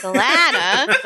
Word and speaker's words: Alana: So if you have an American accent, it rhymes with banana. And if you do not, it does not. And for whatever reason, Australians like to be like, actually, it does work because Alana rhymes with banana. And I go, no, it's Alana.